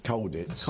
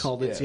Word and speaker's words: Colditz: experience of Colditz. Is that pronounced right? – Colditz. – Colditz, yeah.